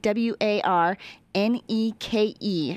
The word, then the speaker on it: Warneke: Warneke Paper Box Company, W A R N E K E. Again, W A R N E K E. Did I say that right? Warneke. – You did.